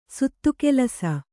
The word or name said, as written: ಸುತ್ತುಕೆಲಸ